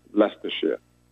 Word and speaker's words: Leicestershire: Liechtenstein? – So the town is Melton Mowbray, but the county in England, even though it looks like it's spelled very strange, is actually just pronounced Leicestershire.